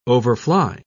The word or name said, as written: overfly